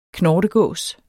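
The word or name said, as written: knortegås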